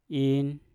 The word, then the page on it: in: Mpur